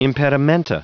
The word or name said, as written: impedimenta